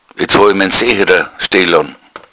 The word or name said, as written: secherer